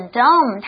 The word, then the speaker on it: dumb: So, 偶尔呢 Duncan 也会画一些他觉得很愚蠢、愚笨和烦人的事物哦。注意哦，这里我们提到的 dumb, 它的 B 后面是 silent, 它是不发音哦。还有 annoying, 就是很烦啊，很讨厌啊，甚至有时候我们说有个人很机车，我们也会用 annoying 这个字哦。